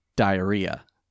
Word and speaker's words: Dierya: It is spelled D-I-E-R-Y-A. – Okay. – I'm going to let you ruminate on that for a second. I pronounced it Diria. – Yeah. – It is pronounced diarrhea. – No, why? – Diarrhea. Diarrhea. Diarrhea.